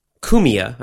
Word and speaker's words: Cumia: Anthony Cumia, Cumia, I believe is how you pronounce it, is a radio host.